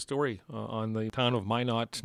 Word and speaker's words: Minot: Minot. Am I pronouncing that right? – It's called Why Not Minot.